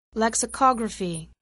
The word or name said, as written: lexicography